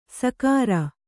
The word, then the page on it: ಸಕಾರ: sakāra